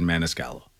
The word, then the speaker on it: Maniscalco: You know, I will say that the last comedian I saw was that Italian Sebastian Maniscalco. – Mm-hmm. Okay. – I think that's how you say his name. – Yeah. Right.